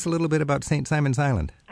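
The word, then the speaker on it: Simons Island: St. Simon's Island.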